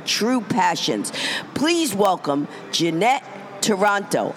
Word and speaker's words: Taranto: Because when we did that Facebook Live, if anybody's watching, her name is spelled T-A-R-A-N-T-O. You would think it's Taranto, but she said, no, it's Toronto. I said, oh, that's like saying John is spelled S-U-E.